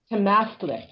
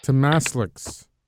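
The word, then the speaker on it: Tamastslikt: Temastlix. – Temastlix.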